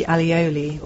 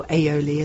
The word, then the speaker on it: aioli: I do apologise to our studio guests for the alioli, or aioli as it's supposed to be pronounced, that I've consumed.